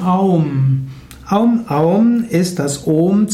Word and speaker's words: Om: Und eigentlich wird Aum tatsächlich Om ausgesprochen, auch wenn im Westen oft die Vorstellung ist, dass es wie Aum ausgesprochen wird. Zwar wird gesagt, dass Om aus drei Silben besteht, A, U und M, und dem Nachklang, aber A und U verschmelzen hier zu Om.